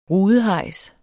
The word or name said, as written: rudehejs